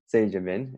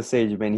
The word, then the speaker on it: Sagebin: His name is Leo, and correct me on your last name, is it Sagebin? Is that how you say it? – Yeah, Sagebin, yep. – Sagebin, okay. Yep. Leo Sagebin,